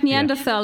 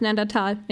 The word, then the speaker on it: Neanderthal: It's like Neanderthal. Yeah. It's actually pronounced Neandertal in German.